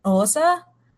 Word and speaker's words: Xhosa: So it's spelled X-H-O-S-A, but there's a click in the beginning. And that's the name of the, that's, it's like Osa or something like that. – Yeah. You got it.